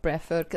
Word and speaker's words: Breathwork: Habe ich es richtig ausgesprochen? – Ja, genau, Breathwork.